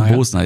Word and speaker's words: Bosna: Ja, und die Bratwurst, beziehungsweise das Bosna, wie mir gesagt wurde. Nicht Bosna, ne? – Bosna. Das hätte ich jetzt gefragt. Wirklich Bosna? – Bosna, ja. Also ich habe es ja im Video tausendmal falsch ausgesprochen.